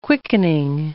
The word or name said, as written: quickening